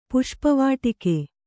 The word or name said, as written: ಪುಷ್ಪವಾಟಿಕೆ